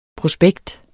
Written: prospekt

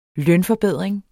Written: lønforbedring

